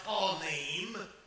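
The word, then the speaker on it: panim (פנים): The word countenance is the Hebrew word pronounced panim, which literally means face.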